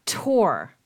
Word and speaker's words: Tor: Isn't don't we have a friend in common who has a grandchild named Thor? – Tor. – It's Tor. – Tor. T-O-R. – It's pronounced Tor. – T-O-R.